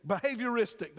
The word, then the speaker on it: behavioristic: So I'm going to just go with behavioral, behavioristic, behavioristic. That's it. I keep trying to put another syllable in there.